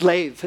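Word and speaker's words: lev (לב): The word heart here is a Hebrew word, and it's lev, is how you say it. L-E-B. It's transliterated L-E-B, but you say it lev.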